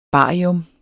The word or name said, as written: barium